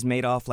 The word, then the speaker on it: Madoff: When I say Bernie, of course, I'm referring to Madoff or Madoff. – It turns out it is Madoff, like Madoff with the money.